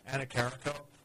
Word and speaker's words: Annecharico: I have the longest last name in the department, so you can find me, Annecharico, and I'd be happy to include anyone who's interested.